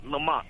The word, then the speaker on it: Lamont: L-A-M-O-N-T. – M-O-N-T-Y? Monty? – No, Lamont. Lamont. – Oh, Lamont. Lamont, gotcha. L-A-M-O-N-T.